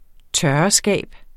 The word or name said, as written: tørreskab